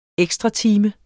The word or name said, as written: ekstratime